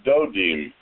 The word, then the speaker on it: Dudaim: Rabbi Yitzhak said, do not pronounce it Dudaim, mandrakes, but rather Dodim, lovers.